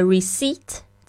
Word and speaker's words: receipt: 可以帮我开收据吗？May I have a receipt? 这个 receipt 虽然它这个拼法是 R E C E I P T，但是呢，那个 P 是不发音的。